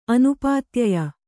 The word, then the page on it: ಅನುಪಾತ್ಯಯ: anupātyaya